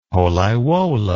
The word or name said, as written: Pollaiuolo